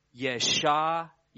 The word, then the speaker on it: Yeshayahu: Let me try to pronounce it in Hebrew, Isaiah. So it's Yeshayahu.